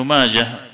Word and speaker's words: ماجه: وماجه بالهاء ولا تنطق بالتاء لأنه اسم أعجمي مثل نفطويه وسيبويه وإن وصلت نعم مثل نفطويه وسيبويه كلها بالهاء وصلا وقطعا.